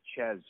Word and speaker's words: Chez: The way that it's spelled, it's C-A-E-S-A-R-A-Y, so they always call me Cesare or Cesara. So I just went with Chez, just cut it short and then put C-H-E-Z, but then people started calling me Shay.